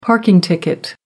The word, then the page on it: parking ticket: American English